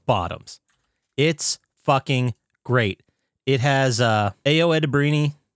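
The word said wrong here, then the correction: Ayo Edebiri: from, sorry, A.O. Edebiri. I believe is how you pronounce it.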